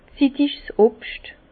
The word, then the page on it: zittig's Obscht: Bas Rhin; Herrlisheim